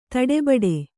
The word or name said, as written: ದಡಬಡ